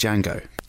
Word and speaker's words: Django: Anyway, Franco Nero obviously played Django. DJ. – No, he didn't. It was Jamie Foxx. – DJ N. – Or Tamura Morrison. – The D is silent.